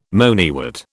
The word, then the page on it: moneywort: British English